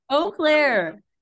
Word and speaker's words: Eau Claire: You clear. – Oh, You clear. – Eau Claire, Wisconsin? – Eau Claire.